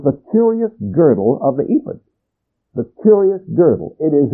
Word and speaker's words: ephod: Now let me tell you why I'm pronouncing it ephod today. Because the Bible I'm using, every one of these words is phonetically written out, and it says pronounce that ephod. But remember, whatever your pronunciation is okay. We don't know how it was pronounced, because there were no vowels. But this man says to pronounce it ephod.